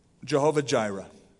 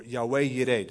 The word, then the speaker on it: Jehovah Jireh: And Abraham called the name of the place Jehovah Jireh, or Yahweh Yireh, depending on how you pronounce it, depending on what part of the country you're from,